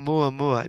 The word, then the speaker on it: Oumuamua: Oumuamua.